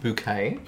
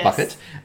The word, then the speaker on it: Bouquet: So she, you know, Hyacinth Bouquet, as she likes to pronounce the surname, not Bucket.